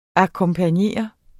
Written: akkompagnere

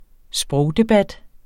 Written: sprogdebat

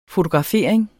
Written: fotografering

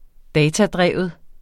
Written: datadreven

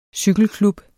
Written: cykelklub

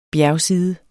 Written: bjergside

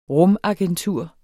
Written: rumagentur

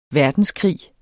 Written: verdenskrig

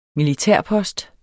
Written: militærpost

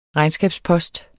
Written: regnskabspost

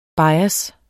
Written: bias

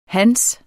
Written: hans

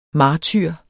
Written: martyr

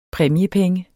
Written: præmiepenge

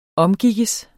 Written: OMGIKKES

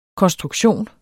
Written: konstruktion

